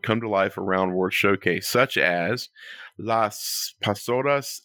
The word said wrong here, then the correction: Las Posadas: at the Mexican Pavilion, the Canadian Holiday Voyagers in the Canadian... No, wait, sorry, I said that wrong. Las Posadas in the Mexico Pavilion.